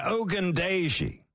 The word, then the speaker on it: Ogundeji: Ogundeji? – Yeah, Ogundeji.